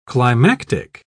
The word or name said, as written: climactic